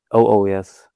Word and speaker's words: Oh Oh Yes: Eure zweite LP, wie spricht die sich aus? Zero Zero Yes oder Oh Oh Yes oder wie? – Oh Oh Yes, haben wir die ausgesprochen.